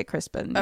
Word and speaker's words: Crispian: It's spelled Crispian, but they would say Crispin.